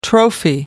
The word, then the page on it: trophy: American English